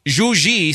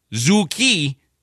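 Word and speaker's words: Zhou Qi: Even though there's a Q, and you would think it's like Zhu Ki. – Ni? – It's Zhu Ji, from what I understand.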